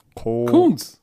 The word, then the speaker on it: Koonce: K-O-O-N-C-E. Wie spricht man das aus? – Kunz. – Kunz, okay. – Kunz. – So, Malcolm Kunz. – Doppel-O ist doch U in Englisch.